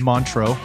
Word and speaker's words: Montreux: Yeah, you were saying it right. It is Montreux.